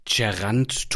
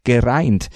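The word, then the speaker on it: Geraint: Das ist nämlich ein walisischer Vorname und den spricht man tatsächlich fast so aus, wie man ihn schreibt. Gereint. Auf YouTube habe ich eine kleine Aussprachehilfe für dich gefunden. – Ger is in egg, reint is in pint.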